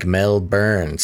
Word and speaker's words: Melbourne: Flippy Official mentions, Basil, we don't pronounce the O in Melbourne. It's more like Melbourne. So Melbourne. Two things, it's called Melbourne.